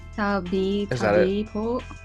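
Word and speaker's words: tabi-tabi po: Tabi-tabi-po. Did I say that right? – That works.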